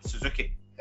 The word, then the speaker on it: Suzuki: I think, you know, when Diamond Mine was first advertised and stuff, where it was Roddy, Tyler Rust, and was it Sudeiki was the other guy? Is that how you pronounce his name? – It's Hatchaman, Suzuki.